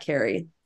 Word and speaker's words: Carie: So that's how they got Carie.